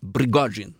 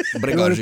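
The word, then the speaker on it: Prigozjin (Пригожин): Jag tror vi säger Prigozjin. – Ja, Prigozjin. – Prigozjin. Det är perfekt uttal.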